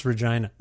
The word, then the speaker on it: Regina: It's pronounced Regina.